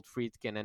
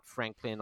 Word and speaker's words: Friedkin: You know, it's very difficult for some Roma fans to remember that Friedkin is actually called Friedkin and not Franklin or Franklin.